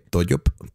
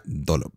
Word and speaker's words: Dollop: Se escribe con doble L, Toyop, pero se pronuncia Dolop.